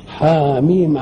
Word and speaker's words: حم: حاميم عين سين قاف. حاميم كانت مرت قبله، إنما حكت عين سين قاف وما جاتش، فعرفنا حاميم تنطق كده زي ياسين وزي طه وإيه حاوميم، إنما كنا عين سين قاف دي ما جاتش، وكنا هربنا ولا صححناش اللوح، فهو دري اننا سيبنا المدرسة وخرجنا.